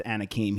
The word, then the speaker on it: Anakim: Like Anakin Skywalker, but with an M at the end, or it pronounced Anakim.